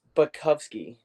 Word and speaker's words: Butkowski: Everyone pronounces it like that, but it's Butkowski.